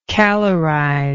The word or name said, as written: calorizer